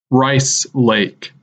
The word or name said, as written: Rice Lake